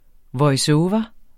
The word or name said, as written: voiceover